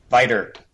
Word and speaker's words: Vita: Vita. I can't keep my pronunciations. – Viter. – I'll take it. – I'm sure one of our UK staff members would endorse us calling it the Viter.